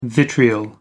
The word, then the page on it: vitriol: American English